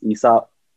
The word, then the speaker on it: Aesop: It's kind of like Aesop Rock, but it's pronounced Aesop.